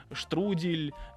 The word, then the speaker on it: штрудель: Конечно же, э, все, что связано с яблоками, штрудель, яблочный пирог. – Мы, ну, э, мы и... выяснили, что надо говорить правильно штрудель. Штрудель. Да.